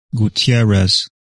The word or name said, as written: Gutierrez